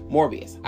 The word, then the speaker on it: Morbius: and I'd want to see more from Morpheus. I would not want or oh, Morbius. I'm sorry, I keep saying his wrong name wrong. Morbius.